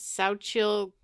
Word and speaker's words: Xochitl: The actress, her name is Xochitl Gomez. That's how you pronounce her name.